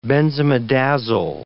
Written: benzimidazole

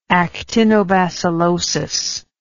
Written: actinobacillotic

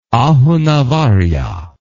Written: Ahunavarya

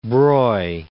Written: Broglie

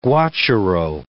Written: guacharo

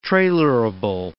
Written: trailerable

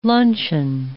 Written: luncheon

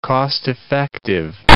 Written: cost-effective

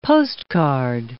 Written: postcard

